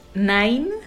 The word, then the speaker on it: Nain: Otra es Nine, que suena como Nine de 9, pero se escribe N-A-I-N.